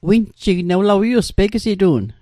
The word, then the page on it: winjig: Mi'gmaq